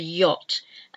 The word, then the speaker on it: yacht: Yacht, a boat, a type of boat, a sailing boat, is a yacht, and it's spelt Y A C H-T, yacht. So it's a silent CH. Both of those letters are silent.